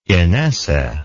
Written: inesse

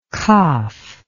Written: kaf

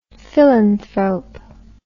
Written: philanthrope

